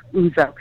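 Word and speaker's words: Ouzo: It's O-U-Z-O. It's like the Greek liquor, Uzo, because he's a little crazy like Uzo.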